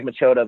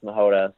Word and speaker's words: Machota: Yeah, so my name is spelled, my last name is spelled Machota. So it looks like Machota, but it's pronounced Mahota.